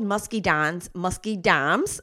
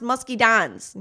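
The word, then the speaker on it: muscadines: For years and years, I called musky dimes with an M because that's what my grandmother always called them. To this day, my husband and I still call them musky dimes when we're talking to each other because it just reminds me of my mama. But it's actually pronounced musky dines, you know, kind of like Valentine's and Valentine's.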